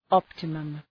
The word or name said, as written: optimum